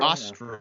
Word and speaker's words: Eostre: There was a festival and a feast called for a goddess, a Germanic goddess named it's spelled E-O-S-T-R-E, so Estro? – Estro. – Ostra. Yeah. Ostra or Estro.